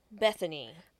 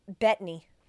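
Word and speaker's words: Bettany: And Paul Bethany. – Bethany.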